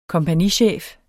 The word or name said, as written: kompagnichef